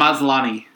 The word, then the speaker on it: Maslany: Maslany. – He is the Tatiana Maslany of this category.